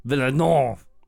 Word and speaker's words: Villeneuve: Denise Villeneuve? Villeneuve? Villeneuve.